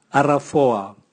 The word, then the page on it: aravoa: Raga